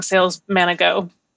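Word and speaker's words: SALESmanago: It is sales manago.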